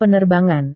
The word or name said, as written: penerbangan